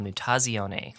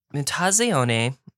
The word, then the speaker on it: Mutazione: And this is, it's pronounced via the Italian word for mutation. It's called Mutazione.